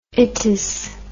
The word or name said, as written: itous